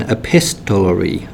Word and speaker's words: epistolary: So the Potato Peel Pie book is an epistolary novel. I hope I've said that correctly. – You have.